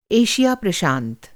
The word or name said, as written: एशिया प्रशांत